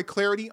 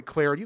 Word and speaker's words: Clardy: Give me the follow at Troy Clarity on Twitter, at Troy Clarity. The last name is spelled C-L-A-R-D-Y.